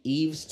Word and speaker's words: Yves: Eve's Tumor. Album's called Heaven to a Tortured Mind. – Like Eve? – No. Yeah, like Eve, but not spelled. It's spelled Y-V-E-S.